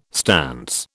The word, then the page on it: stance: British English